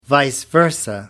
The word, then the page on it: vice versa: American English